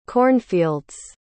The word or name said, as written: cornfields